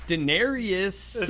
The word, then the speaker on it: Daenerys: Daenerys. – There's no I-U-S. It's Daenerys.